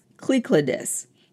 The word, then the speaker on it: Cyclades: Now, this word in Greek is actually pronounced Cyclades, which means encircling islands.